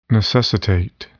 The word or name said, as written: necessitate